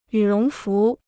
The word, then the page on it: 羽绒服: yǔ róng fú